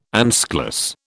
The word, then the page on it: anschluss: British English